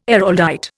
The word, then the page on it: Araldite: Indian